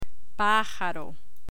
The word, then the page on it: pajaro: Spanish